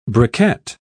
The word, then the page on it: briquet: British English